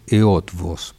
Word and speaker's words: Eötvös: Alguna vez escuché una frase, le escuché a Peter Utrecht, con quien trabajé mucho en Europa, gran músico, también el compositor y director. – Lo pronunciaste fantástico, pero para nuestros oyentes digamos que es Eötvös, porque si no, no van a saber de qué es.